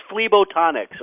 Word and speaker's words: phlebotonics: It is called phlebotonics. There you go. – It's just phlebotonics. – Phlebotonics.